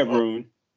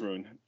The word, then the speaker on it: Rune: Yeah, Rune. No, it's Rune. Yeah, Holger Rune. You said it right.